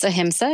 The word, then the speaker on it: ahimsa: So as a ahimsa, and am I saying that correctly? Is it ahimsa? – It's ahimsa, yeah.